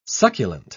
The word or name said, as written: succulent